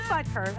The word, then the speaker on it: Butker: Yeah, it is Butker. I said it right.